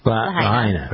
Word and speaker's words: Lahaina: Lahaina.